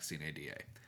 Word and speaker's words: sine die: It is, in fact, sine die, according to how to pronounce it. But in a state where we've got cities like Prague and Miami, I'm not surprised that we might say sine die. It is where we say sine die and not just "sign die.